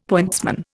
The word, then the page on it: pointsman: Indian